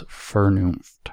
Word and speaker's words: Vernunft: Ultimately, what he calls, what gets called, translated as reason, he calls Vernunft, which we will then, we're also going to spend some time on that later, and I mispronounce it, it's Vernunft. I'm working on it, my German.